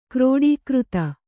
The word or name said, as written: ಕ್ರೋಡೀಕೃತ